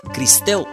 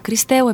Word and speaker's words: Кристел: Кристел е правилното изговаряне на името, нали така? – That is the correct pronunciation. – Точно така, Кристел е правилното произнасяне.